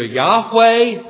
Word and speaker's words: Yahweh: I am, or as it's repeated over and over again, the holy name, pronounced either Yahweh in Hebrew or Jehovah.